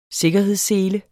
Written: sikkerhedssele

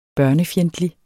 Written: børnefjendtlig

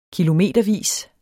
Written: kilometervis

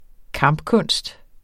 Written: kampkunst

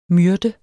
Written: myrde

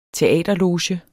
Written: teaterloge

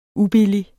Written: ubillige

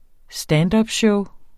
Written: standupshow

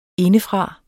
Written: indefra

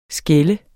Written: skælde

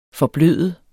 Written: forbløde

